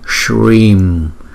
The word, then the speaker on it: Shrim: Chữ S có dấu sắc này chúng ta đọc là sh, sh, Om Shrim. Vì chữ Y này có dấu gạch ngang cho nên chúng ta đọc nó dài hơn chữ Y thường là hai nhịp, chúng ta đọc là Om Shrim.